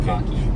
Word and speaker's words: Mach-E: The Mach-E. – Yes. – Okay. – The Mach-E.